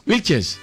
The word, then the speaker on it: Wilches: Buenos días, Puerto Wilcher. Desde Puerto ¿cómo se dice? Wilcher. ¿Dice Wilches? Wilches. Hey.